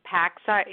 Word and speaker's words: PACSA: And tell us, what does that, am I saying it right? Is it PACSA? – Yeah, so to be clear, that we call the organization PACSA in short, because mm-hmm.